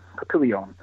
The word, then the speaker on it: Papillion: Obviously, you know, Omaha, they play in the neighboring town, actually, of, I'm probably going to mispronounce it, but Papillion. – Papillion is correct.